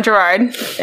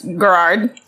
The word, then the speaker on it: Girard: It's not Gerard. It's Garrard. – G-I-R-A-R-D.